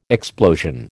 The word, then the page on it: explosion: American English